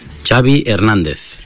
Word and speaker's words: Xavi Hernández: Xavi Hernández.